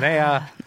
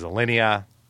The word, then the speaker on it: Alinea: Anton confirmed that I pronounced the name of super fancy Chicago restaurant Alinea incorrectly. I was wrong. Allison was right. I was wrong. I said Alinea. It is Alinea.